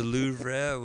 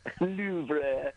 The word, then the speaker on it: Louvre: The Louvre was, Louvre. – Did I not pronounce it right? – I see me that moaning Lisa.